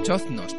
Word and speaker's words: choznos: También conocidos con el nombre de chonzos. O choznos, perdón. Es que cuesta hasta pronunciarlo. Pues sí, sería eso, los choznos o choznas.